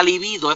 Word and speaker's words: libido: Porque, oye, el exceso de trabajo va reduciendo lo que llaman la, la libido, y así se dice, la libido es femenino con acento en la en la en la i, en la segunda.